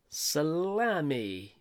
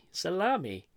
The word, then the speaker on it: Salemme: I'm guessing it's Salami. S-A-L-E-M-M-E. Salami. Salami. It's just spelled differently, folks.